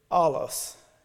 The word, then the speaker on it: allos: The next Greek word is allos. It's spelled A-L-L-O-S, allos.